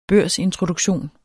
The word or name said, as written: børsintroduktion